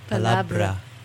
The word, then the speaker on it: palabra: I have a problem with the word in Spanish. Palabra. Palabra. – Palabra. There you go.